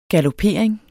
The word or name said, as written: galopering